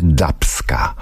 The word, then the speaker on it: Dubska: to teraz będzie dubska. Tak to się powinno wymawiać. Dubska od gatunku dub.